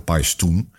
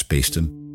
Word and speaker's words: Paestum: En in het klassieke Latijn zou je de stad als Paestum hebben uitgesproken, Paestum. De U wordt als U uitgesproken, AE als een I. Dus Paestum is het in de Romeinse tijd geweest. In de latere Romeinse tijd wordt dat Peestum. Dan wordt die klank E getrokken. En in het moderne Italiaans is het ook nog steeds Peestum of Pesto. Dus dat houden we meestal aan in de uitspraak, dus Peestum.